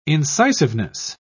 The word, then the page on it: incisiveness: British English